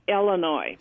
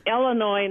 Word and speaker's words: Illinois: But then a common one, of course, is simply the mispronunciation of Illinois. It's not Illinois. Please have some of your folks know that. It's Illinois, not Illinois or Illinois.